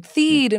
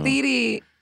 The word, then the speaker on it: Thede: I still keep doing interviews with people, and they're like, Thede. And I'm like, no. – I know. – It's Thede. It's long E.